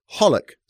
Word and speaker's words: Hollick: First of all, we've got to give you the answer to our mystery wine producer, and it was Hollick. Sort of rhymes with bollock, if you remember.